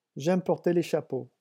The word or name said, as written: j'aime porter